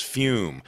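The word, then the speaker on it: Füm: that is Fume, pronounced F-U-M-E, but spelled F-U-M with umlauts.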